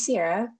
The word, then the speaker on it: Sierra: My name's Sierra, pronounced like the singer.